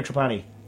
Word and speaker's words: Trapani: Pronounced Trapani or Trapani? – Trapani.